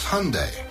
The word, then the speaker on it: Hyundai: It's Hyundai, like Sunday.